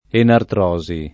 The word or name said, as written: enartrosi